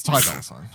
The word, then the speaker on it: Ty Dolla Sign: It's Ty Dolla Sign, I believe is how you say the person's name.